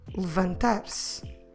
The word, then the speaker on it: levantar-se: You can just ignore it and say levantar-se. Levantar-se. So go from the L sound to the V sound with nothing in the middle. No vowel sound in the middle is necessary because it's an unstressed E, or in Portuguese, E.